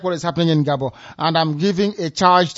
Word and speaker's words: Gabon: In Gabon, where the French pronunciation is Gabon. That is the way it's pronounced in French.